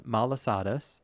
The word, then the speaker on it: malasadas: And I'm sorry if I'm mispronouncing this, everyone, but it looks like it would be pronounced malasadas. It's all A's, M-A-L-A-S-A-D-A-S, malasadas.